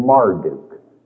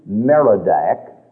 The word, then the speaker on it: Marduk: So this Babylonian god is mentioned in your Bible sometimes with the name Marduk, M-A-R-D-U-K, sometimes with his name Merodach, M-E-R-O-D-A-C-H. And it represents those variations in pronunciation, but it's talking about exactly the same pagan god.